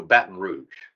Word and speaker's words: Baton Rouge: and that's how you say it, Joe, Baton Rouge.